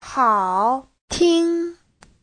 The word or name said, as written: hao3ting1